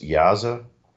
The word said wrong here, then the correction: Daza: Oh, you're talking Yonathan Daza, right? – Yeah.